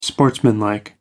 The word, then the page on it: sportsmanlike: American English